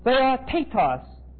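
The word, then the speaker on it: theotetos (θεότητος): It is theotetos. Theotetos. T-H-E-O-T-E-T-O-S.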